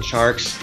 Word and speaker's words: Tjarks: Jonathan Charks. The last name is spelled TJARKS.